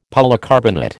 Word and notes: polycarbonate: American English

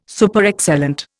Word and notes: Superexcellent: Indian

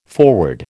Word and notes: forward: American English

